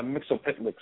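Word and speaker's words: Mxyzptlk: So once again, a Mixopitlick syndrome, that's the right pronunciation, just a short little dude has crazy powers.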